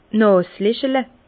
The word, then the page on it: d' Nàselechle: Bas-Rhin; Herrlisheim